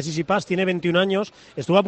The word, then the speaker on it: Tsitsipas: Luego nos hablas de la Davis, pero ha terminado el Masters en Londres y ha terminado con victoria de Tsitsipas ante Thiem en el tiebreak. – Bien pronunciado, además, Tsitsipas, que es como dice el que se dice,